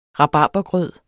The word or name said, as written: rabarbergrød